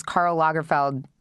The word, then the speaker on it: Karl Lagerfeld: It's also, can I have to say, it's been very interesting because Karl Lagerfeld, did I say his name right? – Yep.